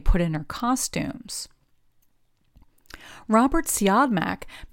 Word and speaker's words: Siodmak: On the back of his coat, he had chalked out the phonetic pronunciation of the three syllables in his name with dash marks, Siodmak.